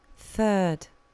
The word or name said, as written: third